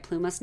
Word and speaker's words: Plumas: Plumas National Forest? – Yeah.